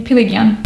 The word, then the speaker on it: Piliguian: Piliguian.